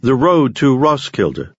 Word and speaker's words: Roskilde: Roskilde.